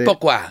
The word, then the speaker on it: pourquoi: se escribe por cui, pero es pourquoi.